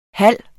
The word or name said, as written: halv